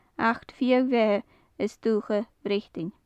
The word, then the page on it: achd fiadh-bheatha 's duthcha: gaelic